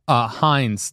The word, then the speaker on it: Hines: Hines, not spelled the same way. H-I-N-E-S, not H-E-I-N-Z.